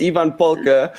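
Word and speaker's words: Ievan Polkka: I mean, your guess is as good as mine, but I think it's pronounced Ivan Polka.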